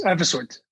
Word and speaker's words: Evisort: You've got quite the career in fintech as a board member and now the co-founder and CEO of Evisort. Is that pronounced correctly? – Yes, Evisort.